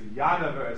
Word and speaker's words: Genova: Rabosai, so here is a place which is called Genova. But you know, things are pronounced differently. The famous Yanover Esrogan, and I've never heard of Yanover, mentioned it yesterday, grew here.